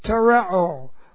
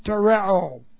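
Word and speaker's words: Tereo (τηρέω): We got the present passive participle of tereo, which looks like this. And this is T long E R E long O. Tereo.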